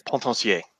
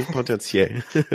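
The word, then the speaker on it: Potencier: Pontancier. – Ah, thank you. Fabien Pontancier.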